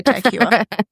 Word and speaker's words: Tag Heuer: Well, last year, Rolex, tag Hua, Hua? I can't say it properly. – Yeah, I say Tag Heuer.